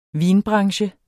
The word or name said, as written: vinbranche